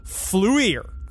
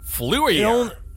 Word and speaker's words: FLIR: Forward-looking infrared, pronounced FLUIR. – FLUIR.